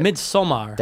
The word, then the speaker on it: Midsommar: I didn't know that that's how you pronounce it. – Midsommar. – That's how Ari Aster pronounces it.